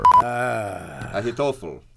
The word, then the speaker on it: Ahithophel: Ahitophel. Emphasize the toe.